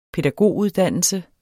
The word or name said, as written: pædagoguddannelse